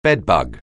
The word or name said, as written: bedbug